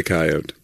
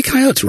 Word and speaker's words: coyote: I've heard him say coyote. – Maybe coyote's right. Why do we pronounce that E? – Because of Wile E. Coyote.